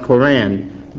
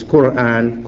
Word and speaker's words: Quran: And in most Western literature and libraries, you'll find the name spelled K-O-R-A-N, and it's pronounced Quran by most of the Western people, but it's Quran. Quran is the pronunciation, is the exact pronunciation as Muslims give it, as Muslims know it, pardon me, Quran.